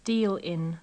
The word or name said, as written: deal in